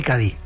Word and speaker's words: Cádiz: Escritor de Cádiz, Cádiz.